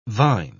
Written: vine

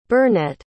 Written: burnette

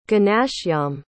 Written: ganashyam

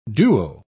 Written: duo

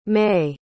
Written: maye

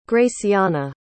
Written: graciana

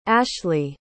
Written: ashleah

